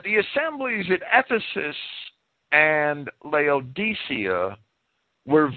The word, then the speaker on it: Laodicea: And among the assemblies which Paul is credited as having founded are those at Ephesus and Laodicea, or Laodicea is the common pronunciation.